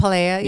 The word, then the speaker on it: Pilea: Pilea.